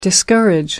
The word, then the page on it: discourage: American English